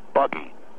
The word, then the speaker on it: Buggy: My name is Buggy. Okay? You can pronounce it Boogie if you want. B-U-G-G-Y, all right?